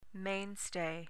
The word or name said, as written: mainstay